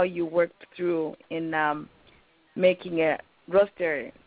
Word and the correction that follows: rotisserie: Am I saying that right? – A rotisserie. – Okay. Thank you, rotisserie.